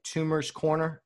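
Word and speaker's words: Toomer's Corner: Tumor's Corner.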